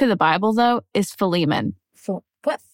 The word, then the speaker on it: Philemon: Philemon. – It's spelled uh, P H I L E M O N, I believe.